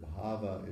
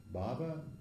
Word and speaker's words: bhava: Bhava, it means, actually, if you look it up in a Sanskrit diction, which I'll do right now, the general meaning with a long A, bhava, I mean bhava, short A is existence, but bhava is a state of being in general.